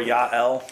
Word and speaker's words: Jael: Jael or Yael, however you want to pronounce the A.